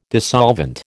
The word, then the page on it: dissolvent: American English